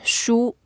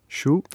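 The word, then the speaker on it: shu (书): they don't bring books to the venue because books is pronounced as shu. – Shu? – Shu, yeah, books, normal books, any book. And shu, exactly the same sound, different character, also means losing.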